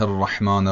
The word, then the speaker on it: الرحمن: بله، اینجا تشدید ر از بین رفته. درستش اینه که بگیم بسم الله الرحمن الرحیم.